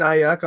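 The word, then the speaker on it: Aiyuk: Yeah, no, I think a guy who kind of like broke on the map a little bit, Brandon Ayuk. Did I say it right, buddy? – You got it. You nailed it. I'm so proud of you. – I wrote down A-I-Y-O-O-O-O-O-O-O-O-O-O-O-O-O-O-O-O-O-O-O-O-O-O-O-K.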